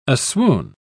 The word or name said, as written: aswoon